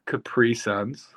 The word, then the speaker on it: Capri Sun: How did you pronounce Capri Suns? – Capri Suns. They're Capri Suns. That is how you, I'm like, I'll die on this hill. It's pronounced Capri Sun.